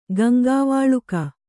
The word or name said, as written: ಗಂಗವಾಲುಕ